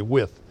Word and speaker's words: Wythe: It's spelled W-Y-T-H-E, but I believe it is pronounced with. Eric, have I got it right? Okay, with.